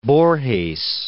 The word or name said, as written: Borges